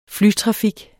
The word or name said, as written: flytrafik